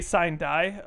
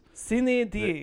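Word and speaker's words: sine die: I say sign die, because I'm an American. Sign die. – We've been practicing this. We've been listening to pronunciations across Cine D. – The interwebs. Yeah. Cine D. – Well, that's the Latin is what it looks like.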